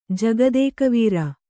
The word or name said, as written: ಜಗದೇಕವೀರ